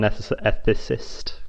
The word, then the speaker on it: ethicist: ethicist. Can't really say that word. – Ethicist. Yeah, I think you find it's pronounced ethicist.